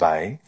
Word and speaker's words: Weil: She was her surname is spelled W-E-I-L, but yeah, pronounced Weil.